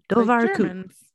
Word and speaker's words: Dobhar Chu: And so I'm guessing it's one of those times when a W is pronounced as a V. So Dobhar Chu.